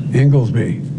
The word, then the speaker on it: Inglesby: I've tapped Dr. Tom, I hope I pronounced it, Inglesby, correct? Is that right, Jeff? – There you go.